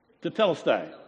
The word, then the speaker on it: Tetelestai: Tetelestai. Go ahead. How do you say that word? Tetelestai.